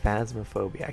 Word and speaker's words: Phasmophobia: I feel like Phasmophobia, that's how you say it, Phasmophobia. I keep saying because I'm used to, does anybody remember the game Phantasmagoria? I keep thinking it's Phantasmagoria, and it's Phasmophobia.